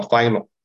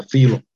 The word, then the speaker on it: Philo: allegorical 解禁法呢，是因为跟主耶稣同时代的那个 Philo，英语发音叫 Philo，希腊文发音叫 Philo。